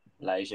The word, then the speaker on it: Lage: I'm just going to say Lage. I'm going to pretend there's an accent. Is that how you said? – Lage, yeah.